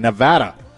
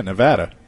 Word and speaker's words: Nevada: Thank you for saying it correctly. – Looking forward to that. Yeah, Nevada. – Thank you. Nevada. – Yeah. – We know Nevada. Thank you so much. People say Nevada. That's incorrect.